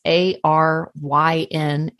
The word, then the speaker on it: Aryn: Um, so my name is spelled a little weird, so I'll spell it out. It's A R Y N, Aryn, and then Michelle is normal.